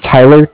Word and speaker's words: Tyler: Okay, my name is Tyler, T-Y-L-E-R. And I'm calling with Cardiff. It's spelled C-A-R-D-I-F-F, as in Frank.